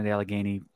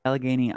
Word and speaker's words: Allegheny: Allegheny, I mean, Lakewood isn't that far from Allegheny State Park.